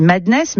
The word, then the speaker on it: MDNS: Avec, je crois, on dit Madness, mais ça s'écrit MDNS.